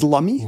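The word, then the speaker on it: Lummi: There's a person here in this household that was born on Lumi Island, so we're up to date on all the Lumi Island news. – So I've been saying Lumi like a chump. It's Lumi.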